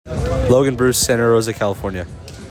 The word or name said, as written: Logan Bruce